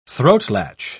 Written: throatlatch

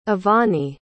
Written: avani

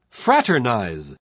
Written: fraternize

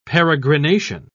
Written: peregrination

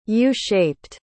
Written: u-shaped

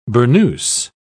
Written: burnoose

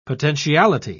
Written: potentiality

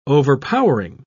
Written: overpowering

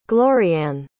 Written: gloriann

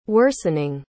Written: worsening

